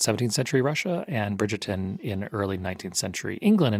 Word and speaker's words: Bridgerton: Bridget Bridgerton.